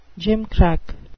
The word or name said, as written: gimcrack